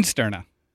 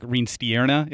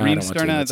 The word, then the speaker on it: Reenstierna: No, seriously, how do you say your name? – Reinsterna. – Reinsterna. Really? Yep. Just like it's spelled? – Yeah. You can put a little accent on the I and say like Reinsterna if you want.